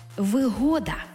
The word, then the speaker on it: вигода: це вигода з упевненим наголосом на другий склад.